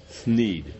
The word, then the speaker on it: Thneed: Thneed. Thneed. That's how you pronounce it.